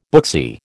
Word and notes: booksy: American English

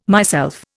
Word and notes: myself: Indian

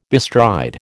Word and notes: bestride: American English

